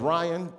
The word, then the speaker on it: Rion: Uh, my name is Ryan, but it's spelled R I O N. And I take time to spell it because some people are stupid. That's the main reason, I, t- I promise you. Because some people see it R I O N, and like, that's not Ryan, that's Rion.